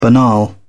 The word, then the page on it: banal: British English